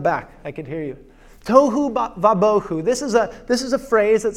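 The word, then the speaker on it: tohu vabohu (תהו ובהו): And it's described as being formless and empty, which in Hebrew is pronounced tohu vabohu. And yes, I'm going to make you learn this phrase with me. Everyone with me. Tohu vabohu. Again, tohu vabohu.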